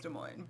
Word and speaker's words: Des Moines: Des Moines? Is that how you say it? – I think I just... – Yeah, it's Des Moines, but whatever.